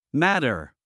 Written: matter

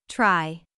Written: try

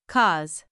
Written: cause

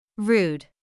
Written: rude

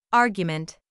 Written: argument

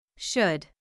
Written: should

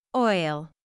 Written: oil

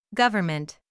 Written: government

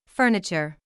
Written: furniture

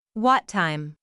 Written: what time

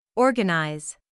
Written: organize

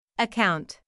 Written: account

